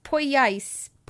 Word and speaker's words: Poyais: which was called the Poyas scam. – I'm not certain that that's how that would be pronounced. – Poyais.